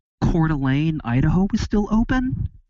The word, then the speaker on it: Coeur d'Alene: Coeur d'Alene. – Coeur d'Alene. – Coeur d'Alene. – Think like French pronunciation.